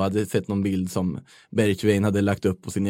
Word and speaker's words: Bergwijn: Han hade skrivit att så här uttalas det, alla kommentarer. Och då läste jag i alla fall det han skrev som Bergwijn.